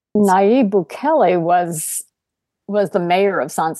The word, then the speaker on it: Bukele: How did this particular president, is it Bukele? Is that how you say his name? – Nayib Bukele. Yeah.